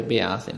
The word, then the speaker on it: Beate: Però c'è una donna nella cancelleria, un'altra donna, che si chiama Beate Baumann, penso si debba pronunciare Beate, no? – Sì, sì.